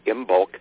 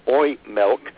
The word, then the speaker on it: Imbolc: I remember when I used to say, pronounce it Imbolc, because because it's spelled I-M-B-O-L-C. And then I tried to learn Irish Gaelic and found out that's pronounced oil milk.